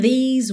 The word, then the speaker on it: these: I know there is hope in these waters. Então, aqui, I know there is enrola a língua na junção, ok? Ho, o E é mudo. In these, que rima com cheese, ok? Aquele som comprido.